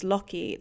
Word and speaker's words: Loki: You've probably been noticing that I've been referring to him as Loki this whole time, which is the correct pronunciation in Old Norse.